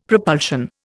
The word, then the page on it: propulsion: Indian